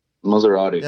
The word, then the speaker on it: Maserati: Maserati.